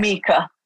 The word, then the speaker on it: Mika: But at the same time, like, your main character does, you know, is it Mika or Micah? I pronounced it Mika in my brain. – Mika.